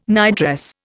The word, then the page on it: nightdress: Indian